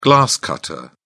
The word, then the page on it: glass-cutter: British English